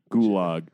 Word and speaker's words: Gulag: But she's like, Clark, you've been saying Gulag this entire time. Just hard G Gulag.